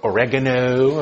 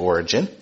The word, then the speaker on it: Origen: One of them is a man named Origen, or Oregano, or Oregon, however you want to say his name. It's actually Origen.